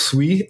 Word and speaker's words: Sui: So it's pronounced SWE, as in you're saying sweet.